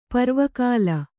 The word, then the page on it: ಪರ್ವಕಾಲ: parva kāla